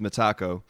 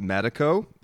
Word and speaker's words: Metako: Right, so the original pronunciation is Metako. If you're from America, quite a lot of people pronounce it Metako because of the way the syllables go together.